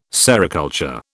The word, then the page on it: sericulture: British English